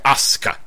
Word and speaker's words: Asuka: It looks like it should say Asuka, but they're pronouncing it Asuka.